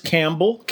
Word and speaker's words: Campbell: It's just Campbell? Like the soup. It's just Campbell, like Campbell soup.